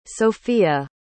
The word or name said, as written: sofia